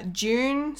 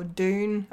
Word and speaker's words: Dune: June, so when June comes out next year, uh, or Dune.